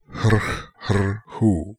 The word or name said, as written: Hrkh hr hoo